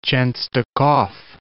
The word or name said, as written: Chenstokhov